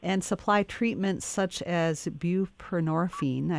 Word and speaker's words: buprenorphine: Is it buprenorphine? – Buprenorphine, yes.